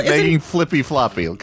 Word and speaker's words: Making Flippy Floppy: Okay, this song is called Making Flippity Flop. – Making Flippy Floppy. Come on, let's get it. – Oh, Flippy Floppy. Sorry, I'm just adding more syllables to it.